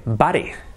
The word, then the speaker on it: bare: Escrito bear. Pero we also have una palabra que se escribe bare, B-A-R-E.